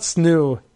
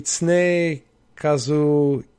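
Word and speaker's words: Tsunekazu: No, it's not Tsunu. It's Ne. So it'd be Tsune Kazu Ishihara.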